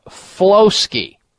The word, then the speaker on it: Floski: F as in Frank. Floski. You know, as in rhyming with brewski.